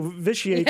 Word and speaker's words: vitiate: It's spelled V-I-T-I-A-T-E. Vitiate. – Well, you want to make that first T hard, don't uh-huh. – you? Vitiate.